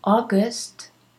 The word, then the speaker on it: August: August, remember, A-U is usually O, as in audio, A-U-D-I-O, audio, as in August, A-U-G-U-S-T, August, as in restaurant, R-E-S-T-A-U-R-A-N-T, restaurant, okay?